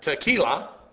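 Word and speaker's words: techilla: Here you have the word tequila, not the kind you drink, by the way. T-E-C-H-I-L-L-A-C. It's spelled different. It's T-E-C-H-I-L-L-A. Tequila.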